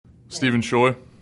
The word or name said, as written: Scheu